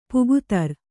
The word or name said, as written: ಪೊಗುತರ್